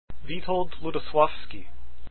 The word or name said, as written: Lutoslawski